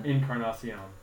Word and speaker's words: Encarnacion: They went and got Edwin Encarnacion. I can't say his last name. Help me out here. – Encarnacion.